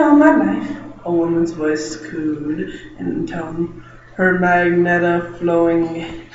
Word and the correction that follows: magenta: Some would pronounce that magenta.